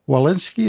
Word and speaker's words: Walensky: The CDC director now, Rochelle Walensky, is that how she pronounces his name? – Mm-hmm.